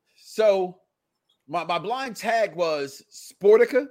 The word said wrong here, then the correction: Sporkita: Did I say it right that time? – Sporkita. – Sporkita.